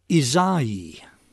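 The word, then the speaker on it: Ysaÿe: I had to look up a pronunciation on Wikipedia. They say it's Isai.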